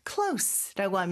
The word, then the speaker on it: close: close라고 하면은 여기서 S 발음으로 해주셔야지 Z 발음으로 하면 닫다라는 뜻의 동사가 된다고 했어요.